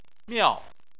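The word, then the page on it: miao4: tone 4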